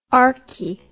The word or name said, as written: arche